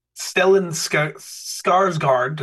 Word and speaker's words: Stellan Skarsgård: is Stellan Skarsgård.